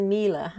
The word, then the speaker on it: Miele: It's a Miele.